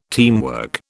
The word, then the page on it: teamwork: British English